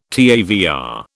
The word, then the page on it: TAVR: British English